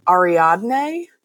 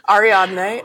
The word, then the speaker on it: Ariadne: So he shares a bathroom in his apartment suite with Ariadne. Is that how you pronounce it? – That's how I say it. – Yeah. Okay, great. Okay, that's what we're going with. Here we go. Ariadne, a dragon shifter and one of the best fighters that the Vipe Queen currently has on her impressive roster of slaves.